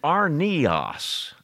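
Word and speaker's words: arnios (ἀρνίον): But there's a word for little lamb, and it's arnios, an I added in that word. Arnos is a lamb, but arnios is a little lamb.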